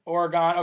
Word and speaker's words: Oregon: And apparently, oh, somebody said pronounced Oregon. Oregon. Okay, my bad.